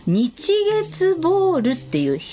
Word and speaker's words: 日月ボール: 日月ボールっていう日の、お日様の日にお月様の月と書いて日月ボールって読むんですけど、これを広島県呉市の江草浜地さんっていう職人さんが考案したんですって。